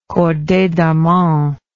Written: corday d'armont

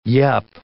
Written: yup

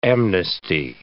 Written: amnesty

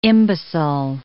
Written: imbecile

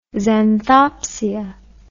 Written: xanthopsia

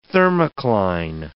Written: thermocline

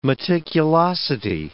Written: meticulosity

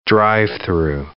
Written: drive-through

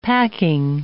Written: packing

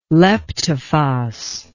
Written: leptophos

